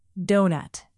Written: donut

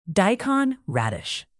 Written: daikon radish

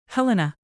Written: helena

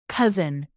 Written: cousin

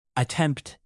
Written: attempt

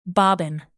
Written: bobbin

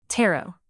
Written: tarot

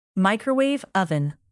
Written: microwave oven

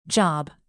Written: job

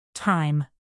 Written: thyme

